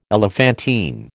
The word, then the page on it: elephantine: American English